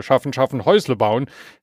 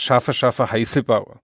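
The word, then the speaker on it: schaffe, schaffe, Häusle baue: Es heißt nicht, oho, ein Schwabe, schaffen, schaffen, Häusle bauen, sondern es heißt, schaffe, schaffe, Heiße baue.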